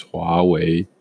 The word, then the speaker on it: Huawei: So I think the proper pronunciation is Huawei.